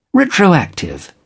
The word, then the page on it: retroactive: British English